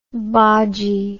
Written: bodgy

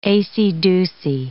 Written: acey-deucey

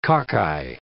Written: cocci

